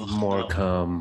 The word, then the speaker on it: Morecambe: It looks like it, but it's Morecambe.